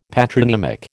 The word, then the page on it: patronymic: American English